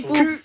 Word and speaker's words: Quipu: Et si vous pensiez que c'était déjà vertigineux d'imaginer la Niakea, attendez qu'on vous parle de, comment, Kipu? – Kipu, oui. – Ça s'écrit Kipu. Q-U-I-P-U.